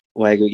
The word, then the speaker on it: Wagyu: Is that how you say it? Wagyu? – Wagyu, yeah. Yeah, whatever.